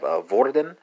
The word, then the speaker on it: hvordan: Translate the Italian to the Swedish, you're gonna have her, spelled H-U-R. Translate the Swedish to the Norwegian, you're going to have Vorden, spelled H-V-O-R-D-A-N. So remember that that initial H is going to be silent, right?